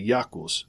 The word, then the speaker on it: Iacchus: This IHS is the Iacchus of the Greeks, the god of wine, pronounced with a hard C, and not the soft C from words like sibyl, or civil, or circular, with kus being its termination.